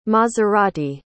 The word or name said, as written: Maserati